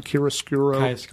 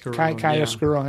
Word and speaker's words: chiaroscuro: Chiaroscuro. – Chiaroscuro.